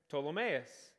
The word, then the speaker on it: Ptolemais: When we had finished the voyage from Tyre, we arrived at Ptolemais. Did you know the P is silent? Yeah.